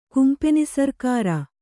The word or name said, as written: ಕುಂಪೆನಿಸರ್ಕಾರ